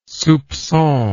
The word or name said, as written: soupcon